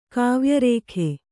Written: ಕಾವ್ಯರೇಖೆ

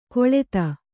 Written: ಖಳತಿ

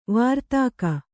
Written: ವಾರ್ತಾಕ

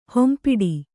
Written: ಹೊಂಪಿಡಿ

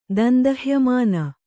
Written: ದಂದಹ್ಯಮಾನ

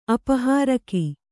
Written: ಅಪಹಾರಕ

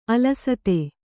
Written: ಅಲಸತೆ

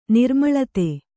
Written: ನಿರ್ಮೂಲಿತ

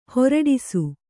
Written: ಹುರುಡಿಸು

